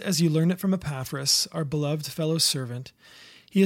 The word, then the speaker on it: Epaphras: but you just don't hear Epaphras a lot, and I can barely say his name, so Epaphras.